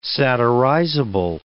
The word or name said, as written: satirizable